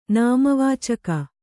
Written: ನಾಮವಾಚಕ

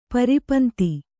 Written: ಪರಿಪಂಥಿ

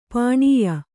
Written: ಪಣ್ಯ

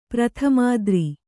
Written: ಪ್ರಥಮಾದ್ರಿ

